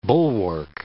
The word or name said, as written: bulwark